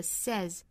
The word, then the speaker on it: says: 我们说到原型应该读成双元音的 say，然后后面加上了 s，应该是读单元音的 says。